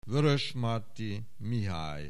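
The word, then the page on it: Vörösmarty, Mihály: Hungarian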